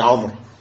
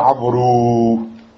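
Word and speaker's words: Amr (عمرو): Seulement, ce waou, on ne le prononce pas. Tu dis pas Amrou.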